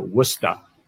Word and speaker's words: Worcester: in Worcester or Worcester as we're supposed to pronounce it here.